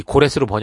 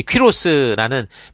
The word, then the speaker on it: 퀴로스: 한국 같은 경우에 처음에 이제 우리가 이게 성경을 음역할 때 이제 그 옛 우리의 그 이 선배들이 조상들이 이 고레스로 번역하는 바람에 고레스라고 우리는 읽는데 그럼 아무도 못 알아들어요. 우리가 좀 이렇게 성경에 맞게 이렇게 발음을 하는 건 중요한 것 같습니다. 어찌됐든, 이렇게 이 퀴로스라는 페르시아 왕이 세상에 등장하게 되는데, 놀라운 사실은 뭐라고요?